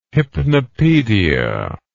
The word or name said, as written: hypnopedia